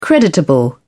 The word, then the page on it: creditable: British English